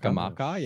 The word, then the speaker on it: Scamacca: Scamacca, yeah.